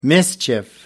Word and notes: mischief: American English

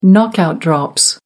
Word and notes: knockout drops: American English

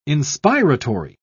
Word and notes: inspiratory: British English

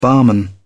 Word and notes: barman: British English

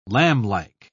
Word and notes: lamblike: British English

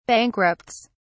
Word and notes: bankrupts: British English

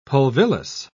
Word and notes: pulvillus: British English

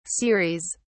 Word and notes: ceres: British English

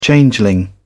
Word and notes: changeling: British English

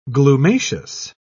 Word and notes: glumaceous: British English

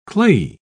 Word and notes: clayey: British English

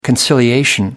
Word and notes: conciliatory: American English

